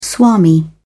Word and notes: swami: British English

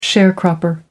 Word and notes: sharecropper: American English

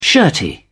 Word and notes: shirty: British English

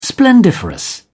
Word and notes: splendiferous: British English

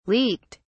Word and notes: leaked: British English

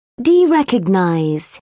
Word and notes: derecognize: British English

